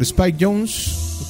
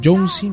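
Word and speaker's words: Jonze: Y justamente el video de esta canción era dirigido por Spike Jones. Otros lo pronuncian Jonesy, no sé por qué,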